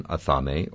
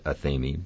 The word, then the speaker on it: athame: We call it an athame, or some people pronounce it athame, but that's neither here nor there.